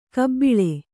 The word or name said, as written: ಕಾಬಳ್ಳಿ